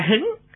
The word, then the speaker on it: Hưng: À, chữ Hưng ở đây đọc là Hứng, Hứng tức là cao hứng.